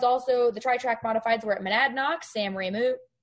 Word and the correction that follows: Ramo: am I saying that right, Kyle? – Ramo.